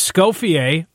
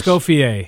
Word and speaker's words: Escoffier: As Scoffier, I think I'm pronouncing that correct. – Oh, you were dead on. That was perfect.